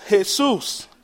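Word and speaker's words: Jesús: "It's pronounced Jesus," I said.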